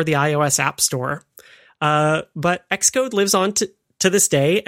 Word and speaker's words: Xcode: And that is another one pronounced X, and it's of course Xcode,